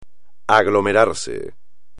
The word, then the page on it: aglomerarse: espagnol